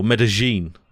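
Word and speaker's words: Medellín: Based in Medellin in Colombia, or Medellin is actually how it's pronounced.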